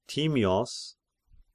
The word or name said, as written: Timios